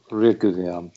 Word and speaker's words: Reguilon: Reguilon.